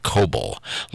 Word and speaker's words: Coble: Glenn Coble. Like Noble, only with a C. Or a K.